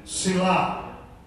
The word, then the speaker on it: Selah: We pronounce it Selah.